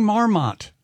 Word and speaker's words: marmot: Don't stop saying marmot. It's marmot. – It's spelled with an O. – I know, but it is marmot, usually pronounced more casually.